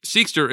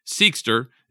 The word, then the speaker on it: Seqster: Seekster, it's spelled S-E-Q-S-T-E-R, Seekster, is making an impact on the industry and even being compared to Apple with its ambitious goals and relentless execution.